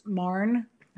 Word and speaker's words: Mahon: Her last name is spelled M-A-H-O-N, but it's pronounced Marn.